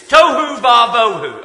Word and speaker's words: tohu va bohu: I'll tell you how to say it, though. It's tohu va bohu, okay? Let's all say it. Tohu va bohu.